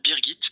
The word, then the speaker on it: Birgit: Alors bon, déjà je me permets juste de corriger la prononciation puisque c'est effectivement en français le prénom Brigitte, mais nous avons choisi sa version allemande Birgit,